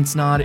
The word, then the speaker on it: Snaadt: My name is Ryan Snaadt.